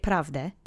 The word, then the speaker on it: prawdę: But we're going to say it prawdę because sometimes that ę gets eaten.